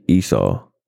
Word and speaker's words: Esau: How you say his name? Esau? Esau? – Yeah. Jacob prepares to meet Esau.